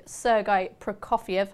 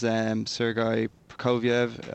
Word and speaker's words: Sergei Prokofiev: Hopefully I pronounced that right. Has he been pretty strong in the betting since the intentions were made clear to run here instead of elsewhere? – Yeah, he's kind of been the standout favourite all along. When Calix entered into predictions, it's close in the betting. But at the moment it stands, Sergei Prokofiev, as you pronounce so well, is 9-4 or 5.